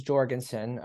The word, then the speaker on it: Jorgensen: So my last name is Jorgensen, Jorgy for short.